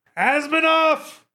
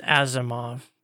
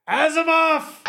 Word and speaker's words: Asimov: Asimov! – I think it's pronounced Asimov. – Oh, right. My bad. – Okay. – Asimov!